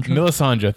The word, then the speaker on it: Melisandre: Theon Greyjoy, Jorah Mormont, Lysandra. I know I never say the name right. – Melisandre, thank you.